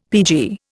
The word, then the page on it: PG: Indian